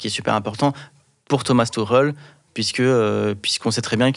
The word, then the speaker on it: Thomas Tuchel: Euh, 1m80, 75 kg, il est trapu, il a, il a cet esprit guerrier en fait que voulait Thomas Tuchel euh, je ne sais pas si je le prononce bien.